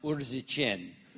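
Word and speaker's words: Urziceni: It's pronounced Urzichin, is the town where they're at.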